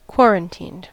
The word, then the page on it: quarantined: American English